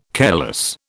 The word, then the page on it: careless: British English